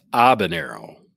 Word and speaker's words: Habanero: Well, first off, the H is silent. It's Habanero. – All right. – Like the band, like the Norse band and Nero, like the emperor.